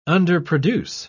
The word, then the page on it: underproduce: British English